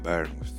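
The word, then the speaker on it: Bournemouth: Μ' αρέσει πολύ αυτό το ναι, Μπέρμουθ. Έτσι, έχει ωραία προφορά.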